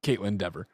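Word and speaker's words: Kaitlyn Dever: That's clearly Caitlin Dever. – Caitlin Dever? – Because it's just never with a D, though.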